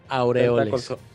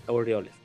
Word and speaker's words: Aureoles: Aureoles. – Aureoles, pues, es, eh, es el gobernador de Michoacán.